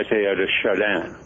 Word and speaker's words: Teilhard de Chardin: And I always screw up the pronunciation of that name, so you'll correct me if I'm wrong, and I suspect I am. – Well, they usually say Pierre Teilhard de Chardin. – De Chardin, okay.